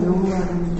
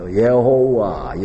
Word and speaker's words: 여호와: 어제 저녁에 얘기했듯이 그 여호와라는 발음은 요드해 와우해에다가 아도나이라고 하는 그 발음 기호를 붙여서 발음한 것이라고 하는 것이 지금 이제 대부분의 학자들이 지지하는 말이거든요. 어떤 사람들은 엘로힘의 발음 기호를 붙였다고 하는데 뭐 이래 붙이나 저래 붙이나 발음은 다 비슷해요. 우리는 여호와라고 우리는 말로 번역했지만은 좀 더 이제 강조해가지고 번역하면 예호우와잖아요. 예호우와, 예호우와. 그러니까 여호와가 됐죠. 여호와.